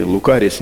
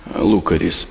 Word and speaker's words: Лукарис: Или Лукарис некоторые произносят, но там ударение поставлено Лукарис.